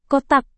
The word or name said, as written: kotak